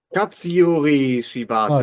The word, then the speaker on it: Katsuyori Shibata: Ich kann den Vornamen nicht aussprechen, deswegen sage ich nur Shibata. – Katsuyori Shibata. Ah, danke dir.